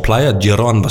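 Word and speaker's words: Playa Girón: Samtidig blev eksilkubaner kubaner kastet ned med faldskærme, Øh, nogle blev også øh, landsat i Playa Diron, som er Svinebugten. Jeg ved ikke, om jeg udtaler det rigtigt. Altså ja. Svinebugten. Jeg tror, Playa Diron var spot on.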